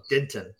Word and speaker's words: Denton: So we have another we have another town here called Denton, um, and the people that are born and raised in Denton pronounce it Denton, but it's spelled Denton, D E N T O N. And uh, but yeah, if you're from there, you're from around there, it's definitely Denton.